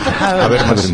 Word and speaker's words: Habermas: Habermas. Una hacha espidada.